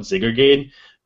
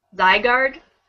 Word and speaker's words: Zygarde: I call it Zygarde. – Zygarde? – There you go. – I always called it Zygarde, too. – I call it Zygarde because there's two G's. And I'm like, Zygarde.